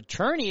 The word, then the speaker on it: Cerny: Andrews Jr. So Cherney is C-E-R-N-Y, but it's pronounced Cherney, as that's a called strike.